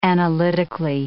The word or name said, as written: analytically